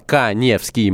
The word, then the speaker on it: Каневский: Соответственно, подписывайтесь на мой Инстаграм Эдуард Каневский.